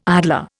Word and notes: idler: Indian

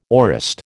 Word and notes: aorist: American English

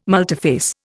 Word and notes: multiphase: Indian

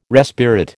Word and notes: respirate: American English

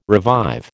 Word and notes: revive: American English

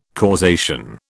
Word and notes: causation: British English

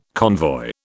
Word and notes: convoy: British English

